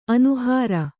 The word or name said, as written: ಅನುಹಾರ